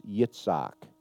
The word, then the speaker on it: Yitzhak: Isaac, the real Hebrew pronunciation is Yitzhak, which means laughter.